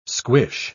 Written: squish

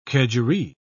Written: kedgeree

